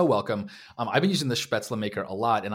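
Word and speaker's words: Spätzle: I think it's Spetzla. And then a bunch of people tweeted at me that it's Spetzla. But I'm sure I'm still mispronouncing it, I'm sure.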